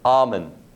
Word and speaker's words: Amen: I'm inspired by Dr. Daniel Amen. It's A-M-E-N like amen or amen, but not pronounced that way.